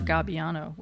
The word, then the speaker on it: Gabbiano: Bar Gabbiano, which is how you say it in Italian.